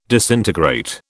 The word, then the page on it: disintegrate: British English